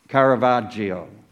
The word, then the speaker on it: Caravaggio: Caravaggio.